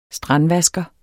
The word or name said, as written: STRANDVASKERE